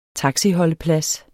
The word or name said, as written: taxiholdeplads